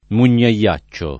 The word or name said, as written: mugnaiaccio